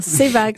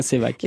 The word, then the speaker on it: Sevac: Donc j'ai eu Sevac, j'ai eu Ravaillac. Mais non, c'est bien Sevac. c'est bien Sevac. Et ça s'écrit S-E-V-A-C.